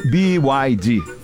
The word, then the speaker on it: BYD: Fetter faz questão de explicar que o correto é pronunciar... – BYD. – Falando o nome das letras em inglês.